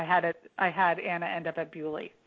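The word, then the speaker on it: Beaulieu: And that's actually the way it's pronounced. I talked to a British guy who was like, no, you're pronouncing it wrong. It's Bewley.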